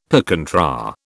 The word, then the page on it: per contra: British English